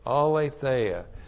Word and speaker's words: aletheia: That's A-L, long E-T-H-E-I-A, aletheia.